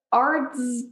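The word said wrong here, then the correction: Arzt: how do you say that name? – Arst. – Arst, has Arst been in anything else?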